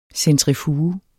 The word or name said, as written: centrifuge